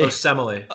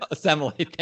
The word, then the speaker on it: Osemele: I think, is it Kelechi? – Osemele. – Osemele, thank you. I don't pronounce Raiders names. – Osemele.